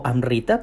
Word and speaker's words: Amrita: Amrut, o Amrita, como se pronuncia en sánscrito, se puede traducir como néctar de los dioses, néctar de vida o la bebida de los dioses.